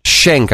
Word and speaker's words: Cenk: So Young Turks is kind of they touch on a lot of no agenda issues, and the guy this Schenk, I think it's Schenk, but you spell it C-E-N-K.